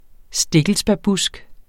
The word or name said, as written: stikkelsbærbusk